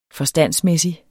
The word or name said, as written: forstandsmæssig